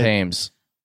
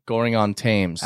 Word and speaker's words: Goring-on-Thames: Goring-on-Thames. – Huh? – Goring-on-Thames.